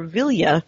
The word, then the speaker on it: Vilya: If you were offered Nenya, Narya, or Vilya, and I don't know if I'm pronouncing it.